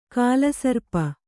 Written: ಕಾಳಸರ್ಪ

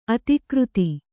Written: ಅಧಿಕೃತ

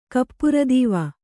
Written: ಕಪ್ಪುರದೀವ

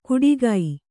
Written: ಕುಡಿಗೈ

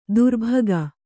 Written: ತರ್ಬುಗ

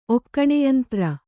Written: ಒಕ್ಕಣೆಯಂತ್ರ

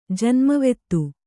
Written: ಜನ್ಮವೆತ್ತು

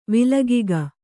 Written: ವಿಲಗಿಗ

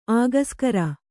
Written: ಅಕ್ಷಾಗ್ರ

